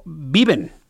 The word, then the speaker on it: Beaven: se llama Eduardo Viven, se escribe Beaven, Eduardo vive en 53 años, el que recibió los impactos, recibió un impacto de bala en el pie, fíjese.